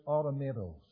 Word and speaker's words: automatos: The Greek word here is pronounced something like this, automatos.